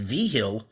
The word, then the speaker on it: Vigil: You know, Nick Vigil of the Bengals, the former Bengals linebacker, is literally his last name is pronounced Vigil. But he told us, just call me Vigil. That's what everybody's always called me.